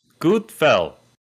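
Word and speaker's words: God kväll: Gutfell. – Gutfell? – Wow. Gutfell. It's spelled G-O-D space K-V-A with two dots above it, L-L.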